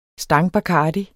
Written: stangbacardi

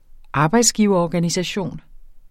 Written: arbejdsgiverorganisation

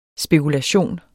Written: spekulation

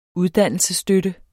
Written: uddannelsesstøtte

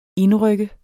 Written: indryk

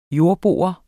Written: jordbo

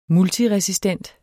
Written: multiresistent

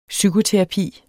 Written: psykoterapi

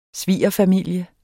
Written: svigerfamilie

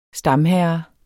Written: STAMHERRENS

